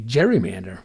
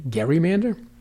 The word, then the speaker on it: gerrymander: How do you pronounce the word? Everybody seems to say gerrymander.